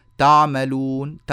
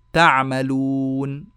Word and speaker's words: تعملون: وهناك ناس ينطقون العين هكذا أع أع فيقول تعملون تع تع هذه العين غير عميقة وغير صحيحة العين الصواب الصحيحة الفصيحة التي كان ينطق بها العرب عميقة بعض الشيء وهو ما لم نعتده في نطقنا العامي فنقول تع والله خبير بما تعملون فاخلع نعليك فاخلع نعليك بهذه الطريقة هذا هو الصواب